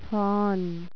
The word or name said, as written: fawn